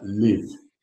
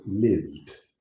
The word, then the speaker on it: lived: Now, another common mistake is not pronouncing the final consonant. So, for example, live instead of lived.